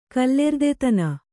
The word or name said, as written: ಕಲ್ಲೆರ್ದೆತನ